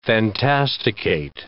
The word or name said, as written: fantasticate